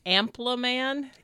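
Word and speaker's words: Ampelmann: But anyways, so there's some other places that had icons, one of which, Ampleman. So wait, say it again. – Ampleman. – Ampleman. – Like pronounce like, ample.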